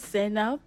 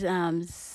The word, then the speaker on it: Zainab: My name is Zainab Nye. – Can you please spell it, your first name and your last name for me? – And my first name is Zainab.